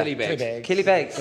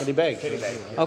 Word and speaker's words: Killybegs: Now, now, how do you pronounce it? Na Cealla Beaga? Killy Beggs. Killybegs.